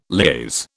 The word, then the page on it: liaise: British English